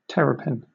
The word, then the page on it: terrapin: British English